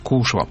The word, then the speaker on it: Кушва: Кстати, нас поправляют по ударению жители Урала, что правильно говорить — Кушва.